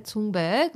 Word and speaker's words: Thunberg: So I found a video that Greta Thunberg, which is how you pronounce her name, and it's always a bit tricky, created.